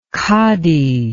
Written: qadi

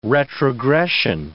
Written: retrogression

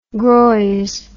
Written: groise

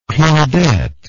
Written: claudette

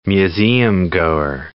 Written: museumgoer

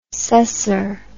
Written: cesser

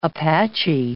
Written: Apache Junction

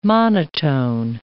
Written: monotone

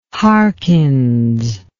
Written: Harkins

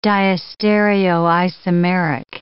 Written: diastereoisomeric

